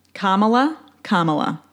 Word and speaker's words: Kamala: I'm voting for Biden and Kamala. Kamala. That's how you say it.